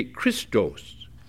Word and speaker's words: Chrestus: A man named Crestus, C-H-R-E-S-T-U-S. Well, in both Latin and Greek, it'd be Christos, Christos. You'd pronounce it the same way.